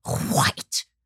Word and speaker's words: white: not just the opinions of what white people, the ultimate insult, white people. You have to pronounce the H before the W or it doesn't sound right.